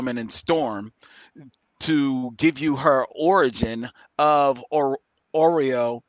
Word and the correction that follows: Aurora: or Aurora. I can never say her name. Aurora. Storm. – Aurora is how I always, yeah.